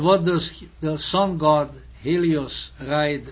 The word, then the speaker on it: Helios: You see, the H, Helios, the H is not pronounced in Greek.